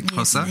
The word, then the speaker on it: Xhosa: And I mean, Siv, you're a Xhosa woman. Mm-hmm. I hope I'm saying that correctly. Xhosa. – Yes, it is.